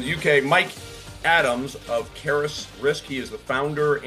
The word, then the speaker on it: Caerus: It's spelled C-A-E-R-U-S, Caris.